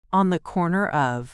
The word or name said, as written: on the corner of